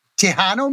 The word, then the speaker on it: Tejano: Tejano music. I'm probably saying it wrong. – No, that's it. Yep.